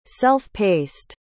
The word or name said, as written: self-paced